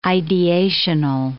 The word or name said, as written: ideational